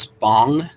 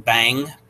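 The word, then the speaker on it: bhang: B-H-A-N-G. I want to say most folks are pronouncing this bong, not bang, but bong.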